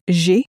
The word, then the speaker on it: J'ai: J'ai. J'ai. – Sounds a bit like the English letter J, pronounced in a French way.